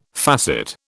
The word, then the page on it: facet: British English